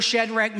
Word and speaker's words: Shadrach: Look at Daniel, Shadrach, Meshach, and Abednego. I always remember how to say the name: shake the bed, make the bed, and in the bed we go.